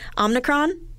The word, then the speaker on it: Omicron: It's kind of like Omicron. No one is saying it right. It is a Greek letter, and in the Greek language, the emphasis is on the first syllable, so the om.